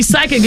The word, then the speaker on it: psychic: So you're right, because when we see psychic, it's spelled P-S-Y, right? – I couldn't tell you. – Yes, yeah, this, yep. – So the P is silent.